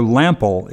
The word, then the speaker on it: Lample: Joe Lamp I'm sorry, Joe Lample is how it's pronounced and spelled, has a great podcast on organic gardening and natural methods of pest control, oh.